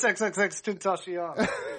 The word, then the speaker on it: XXXTentacion: Don't fucking tell me that that's how you say it. – XXXTentacion? – Acai berry? – Isn't it extension? – No, it's XXXTentacion.